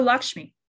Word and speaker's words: Lakshmi: Oh, Lakshmi.